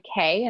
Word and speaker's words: Keay: So, I mean, I'm on Instagram as The Domestic Ginger, and I'm on Facebook as Krista K, and my last name is spelled K-E-A-Y.